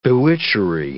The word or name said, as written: bewitchery